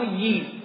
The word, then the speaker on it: ییل: دانشگاه ییل رو به خاطر نوع تلفظ نوشتارش بعضی یاله تلفظ میکنن، یال یا یاله، اما ییل درست صحیحشه.